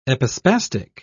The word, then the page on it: epispastic: British English